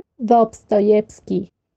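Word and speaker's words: Dostoyevsky: Dobtovievsky, ¿cómo se dice? – Dobstoyevsky.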